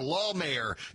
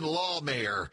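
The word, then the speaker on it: Lahmeyer: For Lawmayor, Jackson Lawmayor. So there we go. Getting the pronunciation right. Jackson is, well, he's in second place now, right now. The way things are looking, we really got to put heavy emphasis on law in the first syllable of the last name, because maybe dealing with the divorce court here pretty soon, if not already.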